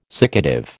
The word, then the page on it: siccative: American English